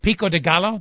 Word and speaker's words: pico de gallo: Pico de gallo, that's how it's pronounced? – Pico de gallo.